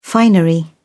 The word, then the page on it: finery: British English